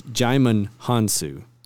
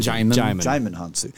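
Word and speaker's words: Djimon Hounsou: It's Jaimin Hansu. Is how you say it. – Jaimin Hansu.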